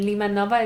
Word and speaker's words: Limanowa: I mean, you are born in, I hope I pronounced the name right, Linanovia? – No, so there's a little correction. So, okay. – Yeah, I am born and raised in Ochotnica Górna, and Limanowa is just the name of town where the hospital was located.